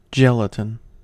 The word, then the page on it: gelatin: American English